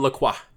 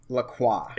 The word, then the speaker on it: LaCroix: LaCroix. – LaCroix. – It's French. And so you have to sound like you've got good health care. And you're confident. – I thought it was LaCroix.